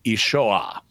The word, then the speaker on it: Yeshua: the name of Jesus in Aramaic would be pronounced Yeshua. And that is a legitimate Aramaic pronunciation, although my Aramaic accent is not great, as I said.